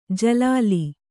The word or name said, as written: ಜಲಾಲಿ